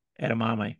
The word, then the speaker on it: edamame: Edamame, yeah. – We're not moving on until you say it right.